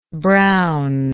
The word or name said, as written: brownness